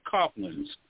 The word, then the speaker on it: copulins: It's spelled C-O-P-U-L-I-N-S, Coplins.